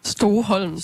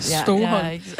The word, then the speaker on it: Stoholm: Stoholm, tror jeg, de udtaler det. Stoholm.